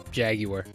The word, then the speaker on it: Jaguar: Because I'm American, so I say it like Jaguar. Even though it should be Jaguar. Which sounds weird in an American accent, you know?